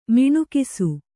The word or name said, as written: ಮಿಣುಕಿಸು